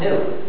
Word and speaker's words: GNU: The reason it's used for so much joking is, according to the dictionary, it's pronounced new.